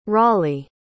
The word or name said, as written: rawley